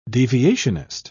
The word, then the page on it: deviationist: British English